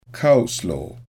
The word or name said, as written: coleslaw